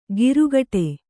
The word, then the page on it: ಗಿರುಗಟೆ: girugaṭe